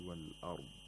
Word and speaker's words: al-ard: On the contrary, in the word al-ard, or the phrase wal-ard, the lam sakina is pronounced because there is a moon letter after the lam sakina.